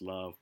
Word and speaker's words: LUV: She goes by the stage name of Love Under Virtue, or L-U-V, which is pronounced love.